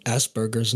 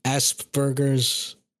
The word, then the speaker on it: Asperger's: So, Asperger's, nice. No, I don't know, there's different ways to pronounce it. Asperger's, Asperger's. I switch from either one.